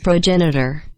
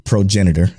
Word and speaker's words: progenitor: Progenitor. – Progenitor, as y'all heard.